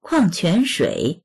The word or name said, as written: kuang4quan2shui3